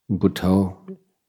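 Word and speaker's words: Buddho: And again, similarly, as with the breath, we can use the mantra Bhutto or some other two-syllable mantra or even four-syllable mantra in conjunction with the breath. As the right foot comes down, for instance, you will in Bhut, and then the left syllable is To.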